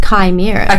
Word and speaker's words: chimera: No, a chimera. – A chimera. – Did you press the little thing that tells you how to pronounce it? I did, and it's chimera.